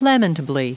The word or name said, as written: lamentably